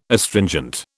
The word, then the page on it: Astringent: British English